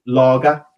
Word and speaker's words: Lage: And, okay, yes, a hell of a lot of games managed less, but it's pronounced Lager.